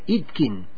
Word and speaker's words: Itkin: La pueden encontrar entonces en el Face como Sara Itkin, se escribe I sin H, ¿no? I T K de kilo I N Itkin, Sara Itkin.